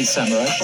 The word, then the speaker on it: Semrush: Ah, well, uh, I, I say semrush, but I think that's more of a European uh, way. Don't, don't Americans say semrush? Now, he said, don't Americans say semrush? And the next person that they show is not an American, she's a Canadian. I mostly say SEM Rush.